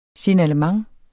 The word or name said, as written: Signalement